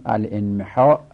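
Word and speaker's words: الانمحاء: مثلا ال انمحا اگر بخوایم صحیح تلفظ کنیم باید گفت الانمحا، الن لن همزه میفته الین محا.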